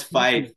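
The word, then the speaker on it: Fife: um, like I mentioned earlier, you were playing in the EIHL for the FIF. Eh, did I say it right? FIF? – No, it's five, but that's close enough.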